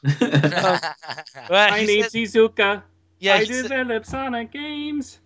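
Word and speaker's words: Iizuka: Izuka. – Izuka. I never pronounce it. – Yeah. I like when people think it's Lizuka.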